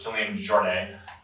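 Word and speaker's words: Kilian Jornet: So the primary author is Killian Jornet. That's, I pronounce it right, right? – Correct.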